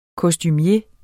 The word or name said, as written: kostumier